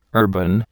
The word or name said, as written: urban